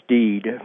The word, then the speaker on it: Stead: Well, actually, his last name is spelled S-T-E-A-D, but I think it was pronounced Steed. I used to pronounce it Stead myself, but somewhere along the line I found out that he pronounced it Steed.